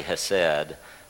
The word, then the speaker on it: hesed: So when I see it, I want to say hesed, but it actually would be pronounced hesed.